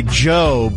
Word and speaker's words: Djo: He goes by Joe, but it's spelled D-J-O. – Yeah. – The D is silent.